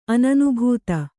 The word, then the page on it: ಅನನುಭೂತ: ananubhūta